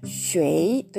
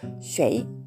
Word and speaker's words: 水: シュエイ」というちゃんとね「イ」を入れて発音する人もいれば「シュエイ」というふうにほとんど「イ」が聞こえない状態で発音する人もいます。